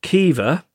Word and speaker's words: Caoimhe: Would it be possible to get a podication for my niece, Kiva? I read ahead because you taught me how to pronounce it because it's... guessing it's an Irish name. – I think so, yeah. – C-A-O-I-M-H-E.